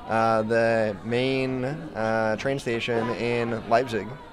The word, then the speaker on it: Leipzig: And I'm happy you pronounce Leipzig the same way as me.